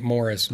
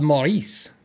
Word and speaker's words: Maurice: I was always pronouncing it Morris, but... – No, Maurice. He was very French. Grew up in Tarrytown. – C'est Bob Maurice.